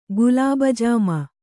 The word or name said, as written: ಗುಲಾಬಜಾಮ